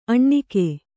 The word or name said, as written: ಅಣ್ಣಿಕೆ